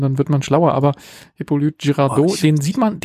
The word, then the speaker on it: Hippolyte: Aber auch dieser andere Darsteller, der den in Love Interest und gleichzeitig den Polizeichef spielt, der heißt, ich kann es nicht aussprechen, Hippolyte. – Oh Gott. – H-I-P-P-O, also Hippo, und dann L-Y-T-E. Das ist sein Vorname. Hippolyte.